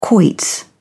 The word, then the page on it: quoits: British English